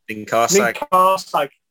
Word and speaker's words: Ninkharsag: And so am I pronouncing that right? Is that NInkharsag? – Well, we always say NInkharsag.